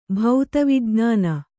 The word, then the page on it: ಭೌತವಿಜ್ಞಾನ: bhauta vijñāna